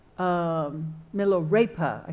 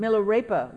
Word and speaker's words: Milarepa: So in the realm of mind, what Milarepa, I keep calling him, pronouncing his name wrong, but Milarepa, what he's saying is, in the realm of mind, our minds are the same as Buddha's mind.